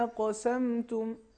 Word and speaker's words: اقسمتم: اسی میں سے یہ لفظ ہے اولم تکونوا اقسمتم، قاف ساکن ہے اور یہ موارد ہیں قلقلہ میں سے ہیں، تو اس کا صحیح تلفظ اس طریقے سے ہے اولم تکونوا اقسمتم، تاکہ قاف میں قلقلہ ظاہر ہو سکے.